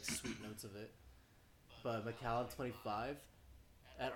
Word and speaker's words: Macallan: It's pronounced Macallan, but I call it Macklin.